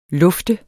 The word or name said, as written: lufte